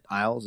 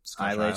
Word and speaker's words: Islay: Is it Isles? Is that how it's pronounced? The Isle. Islay Scotch. Yeah, Islay Scotch.